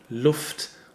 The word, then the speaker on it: Luft: We have L-U-F-T and R-U-F-T. – Yeah, but they are pronounced differently. In Luft, we have a short, opened U sound, O, Luft. And in Ruft, this comes from Rufen, from the word Rufen, where it's a long and closed U sound, Rufen.